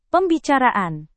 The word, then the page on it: pembicaraan: Indonesian